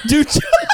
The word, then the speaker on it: Doja: You know, I almost had that moment with that Doja Cat song because I'm like D-O-J-A. – Doja. Doja. – Doja. – Doja.